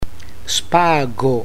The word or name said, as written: spago